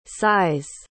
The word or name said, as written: sighs